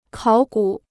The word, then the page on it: 考古: kǎo gǔ